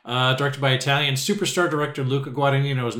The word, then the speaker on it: Guadagnino: A 2024 movie, Challengers, directed by, am I going to pronounce this right, Luca Guadagnino? – Perfect.